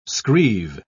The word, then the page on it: screeve: British English